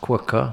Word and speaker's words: Quooker: Okay, this podcast is sponsored by Cooker, pronounced Quooker, spelt Cooker. The invention that delivers instantaneous 100 degrees boiling water.